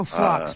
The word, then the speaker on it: phlox: Summer follix. It's a follix plant. – Okay. Oh, flux. – Flux. – P-H-L-O-X. Yep, flux.